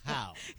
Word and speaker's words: Jau: Muy buenos días, por acá me encuentro con Eduardo Jau, que le decía, así está bien tu pronunciado tu apellido, ¿verdad? – Sí, es Jau. – Jau, así como se escucha, y luego por acá me acompaña Mario, que ya está también por acá.